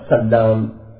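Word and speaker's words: سلّام: ومثل له بقوله نحو بهذين اللفظين، أحدهما بتشديد اللام، يعني أحدهما سلام والآخر سلام.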